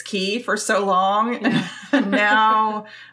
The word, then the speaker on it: Cay: Okay, so first of all, is it K or Key? – Well, that's a very good question. So the correct Bahamian pronunciation would be Key.